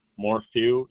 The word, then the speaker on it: Morphew: Cecil, C-E-C-I-L. Morphew, M-O-R-P-H-E-W. – Yeah, that was M-O-R-P-H-E-W? – Yes.